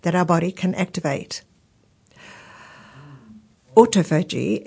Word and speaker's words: autophagy: It's called, I'll spell it for you, AUTOPHAGY, pronounced autophagy.